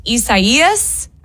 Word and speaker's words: Isaias: It's hard to pronounce. It's pronounced Isaias. I think is how you pronounce it. Isaias.